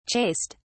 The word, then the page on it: chased: British English